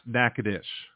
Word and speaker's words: Natchitoches: Natchitoches is pronounced Natchitoches.